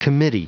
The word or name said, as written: committee